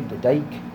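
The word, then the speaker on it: Dyk: So, am I pronouncing that last name right? D-K-Y, Dyke. – Yeah, it looks good.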